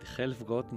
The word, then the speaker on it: Helfgott: Que es espectacular, del pianista David Helfgott, no sé si lo digo bien.